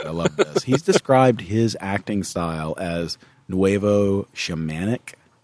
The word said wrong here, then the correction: nouveau: That is the word nouveau.